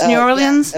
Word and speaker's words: New Orleans: Oh, I can never pronounce, am I pronouncing that right? New Orleans, New Orleans? – Yeah. I mean, New Orleans.